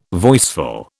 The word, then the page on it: voiceful: British English